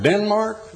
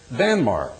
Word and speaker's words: Danmark: We pronounce it Denmark. No, they spell it and pronounce it Danmark, the mark of Dan.